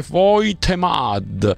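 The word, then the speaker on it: Woltemade: Il tedescone si scrive Voltmade, si legge Voitemad, e Papasar si legge Papsar.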